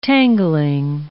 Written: tangling

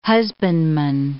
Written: husbandman

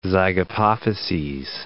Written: zygapophyses

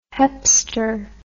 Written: hepster

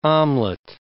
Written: omelet

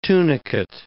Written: tunicate

